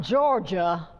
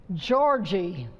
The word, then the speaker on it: Georgie: Not Georgia. Georgie.